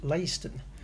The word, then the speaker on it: Leiston: Well, that well-known connoisseur of the English language, Andy Waller, has been in touch to inform me that neither, is correct. He sent me proof that it's pronounced Leiston.